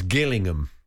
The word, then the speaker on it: Gillingham: She said, sorry to be picky, but the Gillingham in Dorset is, the G is pronounced like G in girl, so it's Gillingham.